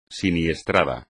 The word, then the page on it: siniestrado: espagnol